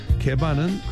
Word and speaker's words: Geba: 오브니를 아프나이 이렇게 발음하시고 게바는 그대로 게바 G-E-B-A 게바 이렇게 발음하시면 되겠습니다.